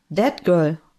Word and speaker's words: that girl: hast du gerade Wet Girl gesagt? – That Girl. – Dieses Mädchen. – Ach so, that girl. – Ja, nicht nass.